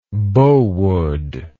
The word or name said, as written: bow wood